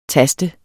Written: tast

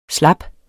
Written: slap

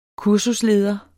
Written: kursusleder

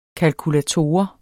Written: KALKULATOREN

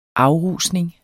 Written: afrusning

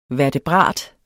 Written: vertebrat

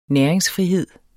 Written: næringsfrihed

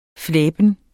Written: flæben

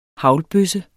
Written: haglbøsse

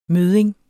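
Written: mødding

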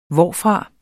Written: hvorfra

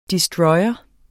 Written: destroyer

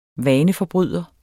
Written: vaneforbryder

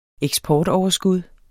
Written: eksportoverskud

